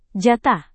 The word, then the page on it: jatah: Indonesian